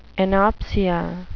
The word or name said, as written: anopsia